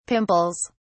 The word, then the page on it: pimples: British English